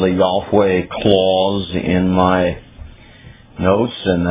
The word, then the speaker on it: Yahweh: the divine name which we best pronounce Yahweh is found in the Hebrew text at those locations.